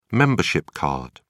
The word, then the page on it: membership card: British English